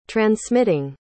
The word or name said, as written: transmitting